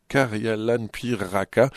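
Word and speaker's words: karjalanpiirakka: Je vais essayer de le prononcer à la finlandaise, mais c'est pas facile. Hein, de préparer un karjalanpiiraka, voilà, qui est une spécialité de la Carélie, avec une finlandaise donc, qui va animer cet atelier cuisine.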